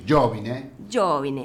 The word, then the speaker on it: Giovine: Sí, Giovine. Porque, viste, son todas palabras esdrújulas.